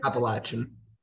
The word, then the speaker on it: Appalachian: Ultimately, after your first year, you didn't go to Appalachian State or Appalachian. How do you pronounce it? – Appalachian. – Okay.